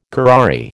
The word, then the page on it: curare: American English